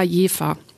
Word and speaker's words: Jever: Ich dachte immer, das spricht man. – Jever. – Ja. – Ja, ja, das Bier heißt, also man ist sich unschlüssig, die Stadt heißt aber Jever.